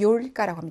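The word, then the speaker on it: 요일까 (ёлка): 나중에 그리고 발음이 요예요. 요, 요. 얘는 예, 여기는 요. 그래서 전나무를 러시아로 요일까라고 합니다.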